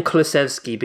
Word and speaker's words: Kulusevski: And to everyone criticising Kulusevski, I'm saying Kulusevski because that's how he pronounced it in the welcome video.